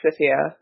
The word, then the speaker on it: Scythia: I'm probably going to get laughed at for my pronunciation of this, because I always pronounce it with a hard C, but Scythia, or Scythia, as some people pronounce it, is actually one of my favorite civs in the entire game.